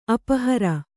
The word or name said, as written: ಅಪಹಾರಿ